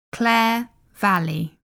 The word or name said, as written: Clare Valley